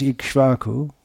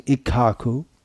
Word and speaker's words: Ikshvaku: So, the sons of Maharaj Ikshvaku, or as Srila Prabhupada would say, Ikaku, in his Bengali pronunciation, were ruling here, no doubt also.